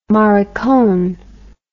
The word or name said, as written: maricon